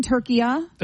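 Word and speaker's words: Türkiye: So you're saying, honey, you're saying that in that language, it's always been turkiya? – That's what he's saying, yes.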